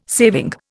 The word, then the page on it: saving: Indian